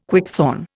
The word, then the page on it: quickthorn: Indian